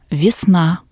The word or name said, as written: vesna